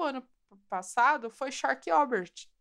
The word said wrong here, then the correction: Sharp Objects: Shark Object. Falei certo? Sharp Objects. É, é isso aí.